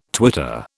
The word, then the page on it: twitter: British English